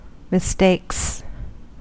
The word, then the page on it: mistakes: American English